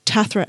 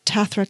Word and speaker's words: Tathra: On Twitter, I'm Tathra ST. So that's Tathra, T-A-T-H-R-A, S-T, as in the abbreviation for street.